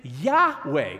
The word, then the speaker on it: Yahweh: He would say, I am worshiping Yahweh, which is the Hebrew way to pronounce the name of the Lord.